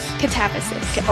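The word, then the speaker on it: Katabasis: Catabasis.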